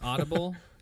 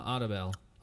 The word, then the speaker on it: Audibel: Is it like Audible, like AutoBell, or is it like auto, like a car? – No, it's Audible. – But Bell? – But they put some sort of... – Twist on AutoBell.